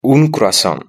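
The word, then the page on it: un cruasan: Spanish (Spain)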